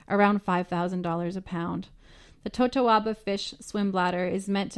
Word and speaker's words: totoaba: There were two endangered species being protected on this operation, the Totoba fish and the Vaquita porpoise. Roy, am I saying that right? – Totoaba. – Totoaba, that sounds much nicer.